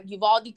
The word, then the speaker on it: Uvalde: Is it called Uvalde? Am I pronouncing that right? – That sounds right. – Okay, Uvalde, Texas, where an 18-year-old, I believe he is Latinx or Hispanic, shot 19 elementary school students and two teachers.